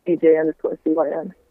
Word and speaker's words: CYN: It's @DJ_CYN.